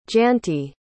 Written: jayanti

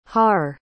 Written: haar

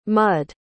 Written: mudd

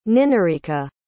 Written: ninarika

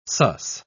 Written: suss

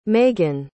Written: magen

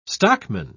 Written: stockman

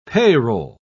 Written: payroll

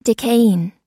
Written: decaying

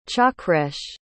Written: chakresh

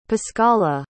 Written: pascala

